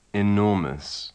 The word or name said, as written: enormous